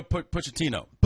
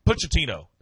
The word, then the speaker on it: Pochettino: How do you say the coach's name? Pacetti? Or what is Pacetti or U.S. – Oh, Mario Pochettino. – Pochettino. There it is.